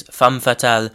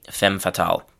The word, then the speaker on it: femme fatale: So the French pronunciation is femme fatale. In English, we usually say femme fatale.